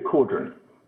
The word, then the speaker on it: Cawdron: And did I say your name correctly? – Very close, Ethan. Peter Cawdron.